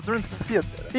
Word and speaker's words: Dream Theater: Aprendi com o Prilo agora há pouco que se pronuncia Dream Theater.